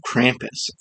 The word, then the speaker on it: Krampus: Because we're diving into the legend of Krampus, Santa's hairy, horned, and hoofed frenemy. – Ooh, I'm glad you said that correctly.